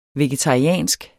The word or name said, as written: vegetariansk